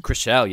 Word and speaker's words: Chrishell: Chrishell, yes. – She's the new Bethany, because she's going to take it to Bethany, and she doesn't care about talking. Like, Bethany's so abrupt and loud. This Chrishell, oh, she's met her match.